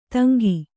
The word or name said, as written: ತಂಗಿ